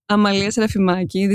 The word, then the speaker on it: Amalia Serafimaki: My name is Amalia Serafimaki.